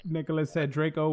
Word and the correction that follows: Drago: was the best dragon in movies. Drago. Yep. Drago. Yes. Sorry, I'm mispronouncing it.